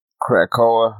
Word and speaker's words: Krakoa: But the Okoa, or I think I'm saying it wrong. – Krakoa, the island, yeah.